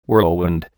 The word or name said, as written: whirlwind